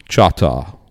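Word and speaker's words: Chahta: He would have used the phrase Chata, which was their word for Choctaw. We have kind of changed the pronunciation of that word over time. The word they would have used was Chata,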